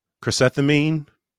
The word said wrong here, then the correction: chrysanthemum: Am I saying that right? – I think I'm going to give it to you. It's chrysanthemum.